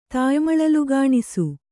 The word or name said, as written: ತಾಯ್ಮಳಲುಗಾಣಿಸು